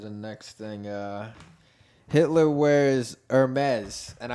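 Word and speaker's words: Hermès: Hermes, the brand is a French. – Like in French, you don't pronounce the H.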